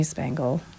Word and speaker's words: Spangle: Jamie Spangle. Yeah, rhymes with dangle.